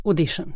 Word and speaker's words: audition: Visst, audition. Det uttalas med å. Audition.